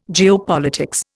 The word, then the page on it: geopolitics: Indian